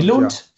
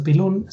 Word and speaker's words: Billund: Billund für Deutsche, ne, weil das D wird ja weich ausgesprochen. Also Billund sagen die Dänen.